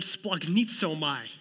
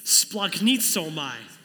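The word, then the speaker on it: splagnitzomai (σπλαγχνίζομαι): Anybody want to take a stab how to pronounce that? It's the word splagnitzomai. Okay, now it's your turn. Ready? Let's say it with me. Ready? Splagnitzomai.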